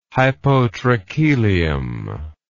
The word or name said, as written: hypotrachelium